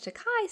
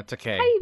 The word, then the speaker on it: Takei: Also, George Takei said in his... Did I say that right? – No, Takei.